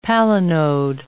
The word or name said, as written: palinode